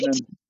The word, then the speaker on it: Weidman: Weidman. – Weidman, thank you. – Weidman, Will, all good.